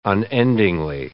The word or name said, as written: unendingly